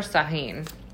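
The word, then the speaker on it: Uğur Şahin: So here's something that I just ran across: the BioNTech CEO, which I'm gonna probably butcher his name, or Sahin. So I think that's how you say it. It's U G uh, U R S A H I N.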